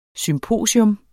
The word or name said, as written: SYMPOSIUM